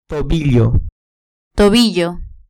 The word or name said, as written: tobillo